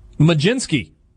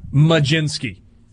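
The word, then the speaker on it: Mlodzinski: Uh, Carmen Blodzinski, as I so enthusiastically pronounced it, not ha- not right. Oh, Majinski.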